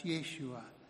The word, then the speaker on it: Yeshua: His name, Jesus, is the anglicised pronunciation of the Hebrew name Joshua, pronounced Yeshua, which means saviour.